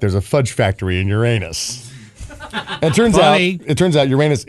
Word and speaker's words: Uranus: Uranus is how it's pronounced.